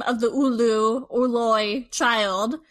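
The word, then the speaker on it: Ooloi: who is an how do we say that? – Uloy. – Uloy. Uloy. Yeah.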